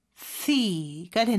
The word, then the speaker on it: healthy: 헬 씨가 아니죠. TH 발음해 주셔야 되는데 여기서는 무성음이에요. 그래서 스처럼 발음하는 것 같은데 발음을 내뿜는 소리로 해주시고요. 그래서 healthy가 되는 거죠.